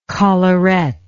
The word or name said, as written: collaret